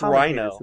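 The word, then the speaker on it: RINO: Oh, the Fox News Channel heavily promotes Reno backers. – It's Rhino.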